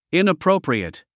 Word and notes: inappropriate: ìnəpróupriət; イナプロォプリエト

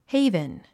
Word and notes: haven: héivn; ヘイブン

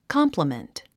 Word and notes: complement: kάmpləmənt; カンプラメント

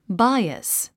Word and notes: bias: báiəs; バイアス

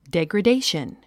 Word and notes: degradation: dèɡrədéiʃən; デェグレェデェション

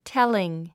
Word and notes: telling: téliŋ; テリン

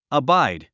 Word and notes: abide: əbáid; アバァイド

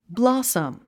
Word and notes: blossom: blɑ’səm; ブラサム

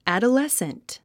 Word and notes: adolescent: æ`dəlésnt; アドレスント

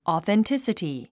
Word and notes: authenticity: ɔ̀ːθentísəti; オーセンチシティ

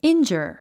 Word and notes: injure: índʒər; インジャァ

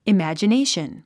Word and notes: imagination: imæ`dʒənéiʃən; イマジネーション

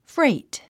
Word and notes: freight: fréit; フレイト

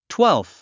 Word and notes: twelfth: twélfθ; トゥエルフス

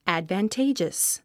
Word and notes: advantageous: æ`dvəntéidʒəs; アドバンテイジャス

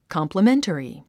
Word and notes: complimentary: kὰmpləméntəri; カンプラメンタリィ

